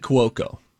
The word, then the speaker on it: Cuoco: So you go Cuoco. – Let me say it again. Kaylee Cuoco. – Okay. I just say Cuoco.